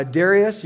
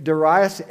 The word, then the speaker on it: Darius: Okay, now you could say it uh, Darius, you could say it Darius, any of those pronunciations uh, uh work correctly.